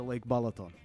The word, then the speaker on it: Balaton: Doubt anybody else here has been to Lake Balaton. – Is so. That how you say it? – Yes.